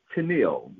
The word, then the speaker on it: Tennial: It is Tennial, like the captain and Tennial.